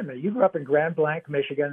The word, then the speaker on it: Grand Blanc: That's exactly the way everybody pronounced it. They did not pronounce it Grand Blanc, which literally means great white. Mm-hmm. Or if you pronounce it, you know, like the way we pronounce it, blank, it is in fact a fairly anonymous, kind of relatively blank spot in the central Michigan landscape.